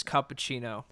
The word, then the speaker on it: cappuccino: It's cappuccino, TV.